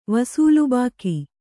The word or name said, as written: ವಸೂಲ್‍ಬಾಕಿ